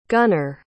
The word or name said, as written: gunnar